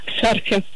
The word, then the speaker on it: шарфе: В данном слове ударение всегда падает только на первый слог, поэтому правильно говорите еще раз. Как? – Шарфе.